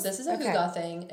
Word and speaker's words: hygge: Spelled H-Y-G-G-E. Pronounced hygge because it's a different language than English.